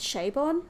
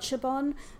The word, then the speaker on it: Chabon: And I know that apparently his name is pronounced Chabon, not Chabon.